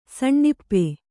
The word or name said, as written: ಸಣ್ಣಇಪ್ಪೆ